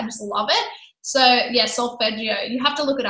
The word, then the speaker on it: solfeggio: So it's SOLFEGGIO, solfeggio.